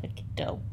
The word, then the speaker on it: taquito: Taquito. – Taquito.